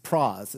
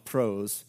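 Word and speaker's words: pros (πρός): Two, now two, that's a very simple word, but that, that is a preposition and it's pros. It's spelled like prose, but it's pros.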